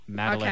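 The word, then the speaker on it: Madalynn: Madeline.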